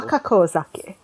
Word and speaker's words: Wakakozake: I will shout out, actually, the anime I mentioned Deb really liked that she introduced me to is Wakozake. Wakozake, I believe, unless I'm missing a syllable. – Wakakozake. – Wakako. – Zake is like sake. So Wakako and her sake.